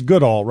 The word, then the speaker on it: Goodall: It is Goodall, right? That's how it's pronounced? – Yeah, Goodall Park, yeah.